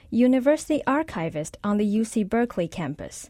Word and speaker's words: Berkeley: Lord Berkeley. – It was a philosopher from Dublin, Ireland. It's actually supposed to be pronounced Berkeley.